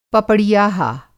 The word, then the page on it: पपड़ियाहा: Hindi